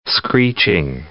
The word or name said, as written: screeching